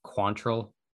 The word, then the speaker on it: Quantrill: I believe we are pronouncing Cal's name incorrectly. I believe it's Quantrill. That's how they were pronouncing it. That's how Matt Vasgersian was pronouncing it.